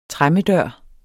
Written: tremmedør

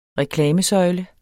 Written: reklamesøjle

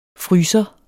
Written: FRYSEREN

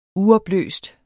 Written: uopløst

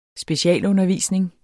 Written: specialundervisning

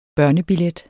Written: børnebillet

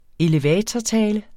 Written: elevatortale

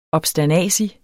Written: obsternasig

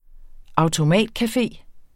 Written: automatcafé

